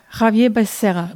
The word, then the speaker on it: Xavier Becerra: Und auch hier, ähm, wie spricht man ihn aus, äh, Rike Becerra? – ja, Javier Becerra.